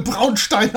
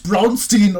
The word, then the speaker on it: Braunstein: Denn der hat das Braunstein und du darfst es auch gerne Braunstein aussprechen. Es ist nicht, Bra Braunstein. Es ist nicht Braunstein oder sowas.